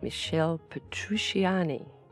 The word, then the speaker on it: Michel Petrucciani: Michel Petrucciani.